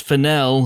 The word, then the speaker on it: Fennell: It's pronounced Fennell, Squadcast name, Terri White.